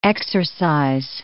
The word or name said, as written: exorcise